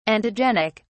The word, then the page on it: antigenic: British English